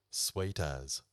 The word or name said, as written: sweet as